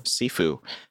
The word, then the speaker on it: Sifu: is Sifu? Is that how you pronounce that? – I believe so. – Sifu.